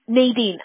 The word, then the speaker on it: Nadine: Nadine. Ajá, no hay modo como pronunciarlo en español. Nadine, Nadine, como usted, lo que es más fácil para ustedes.